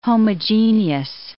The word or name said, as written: homogeneous